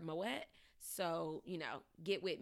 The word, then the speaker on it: Moët: Oh, and while we're talking about Moët, it is Moët with the T. Period. The name is French, but the pronunciation is German.